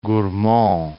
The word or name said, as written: Gourmont